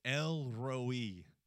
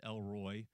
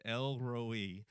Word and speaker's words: El Roi: And that name is El Roi. Sometimes it's pronounced Elroy, but we have a human name, Elroy. So we're gonna pronounce like the Hebrews pronounced it, Elroi.